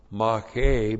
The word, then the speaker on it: mache: And the key word here is mache. M-A-C-H, long E, mache.